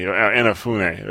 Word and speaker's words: Inafune: Inafune. That's how I believe his name is pronounced. At least that's what we agreed on before the podcast.